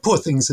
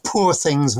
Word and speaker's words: Poor Things: And of course, it's Poor Things rather than Poor Things rhyming with Paw Patrol. Because it has to be very much it is a Glaswegian title.